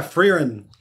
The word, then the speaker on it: Frieren: Is it Frieren? – Oh, yeah, Frieren.